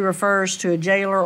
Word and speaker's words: gaoler: G-A-O-L-E-R. – Gaoler. – It's jailer. – It's what? – Jailer.